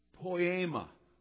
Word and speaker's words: poema: The Greek word is poema. Now that's pronounced, that's the Greek pronunciation. The English word that comes right from it is poem. P-O-E-M-A is the Greek.